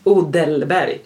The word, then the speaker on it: Odelberg: Men Stefan Odenberg. Jag säger Stefan Odenberg. För jag tycker det är för svårt. Han är Stefan Odelberg. – Mm. – Det tycker jag är ett jättesvårt namn att uttala.